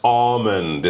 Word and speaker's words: almond: We should mention, this is important, the L in almond is kind of a silent L, all right? So it's not like almost. It's not almond. Almond. – It's more of a, an AU, like August, almond.